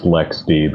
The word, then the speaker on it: LXD: I know it's actually pronounced LexD, but LXD is a great tool.